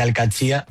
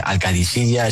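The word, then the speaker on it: Al-Qadsiah: Bueno, se suele decir Al-Katsi, pero también hay gente que dice Al-Kadisiya, es que es difícil, ¿eh? Ah. No es fácil, pero Al-Katsi es lo más fácil.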